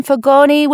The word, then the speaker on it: Fagone: Please forgive me, Jason Fagone with the long E.